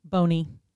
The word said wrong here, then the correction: Bonet: His name is pronounced Bonet. – Oh, my bad. – Not Boney. It's Bonet. – Where's the little? – I don't know. But he says it's Bonet.